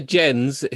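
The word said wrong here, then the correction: Gens: who's a French director. – Wouldn't that be like Xavier Jean? – Yeah, sorry. I absolutely butchered his name.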